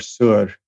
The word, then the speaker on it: Sur: or Sur, that's what it's pronounced like, and it means rock.